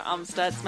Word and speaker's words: Amstutz: And then you can find me everywhere at Sandra Amstutz. My last name is spelled A-M-S-T-U-T-Z.